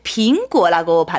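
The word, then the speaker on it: 빙고 (苹果): 네, 빙고라고 발음하잖아요. 네. 근데 방금 우리가 크리스마스 이브 빙안이에 평안한 밤 이렇게 말씀드렸잖아요. 그러니까 빙고하고 빙안 첫 글자 발음 똑같습니다.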